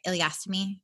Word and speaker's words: ileostomy: So I guess what were your thoughts, when you found, first, how did you get to the point of needing the, am I saying it right? Ileostomy? – Yes.